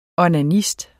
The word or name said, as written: onanist